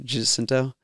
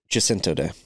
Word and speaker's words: Jacinto: Is that how you pronounce it? Jacinto? – Jacinto Day.